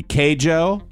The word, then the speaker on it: Keijo: A guy named, how would you pronounce K-E-I-J-O? Would it be Keijo? – Keijo.